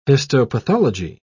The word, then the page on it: histopathology: British English